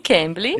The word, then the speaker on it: Cambly: A ah, Cambly, eu, tô, eu espero estar falando certo, Cambly. – Sim, Cambly.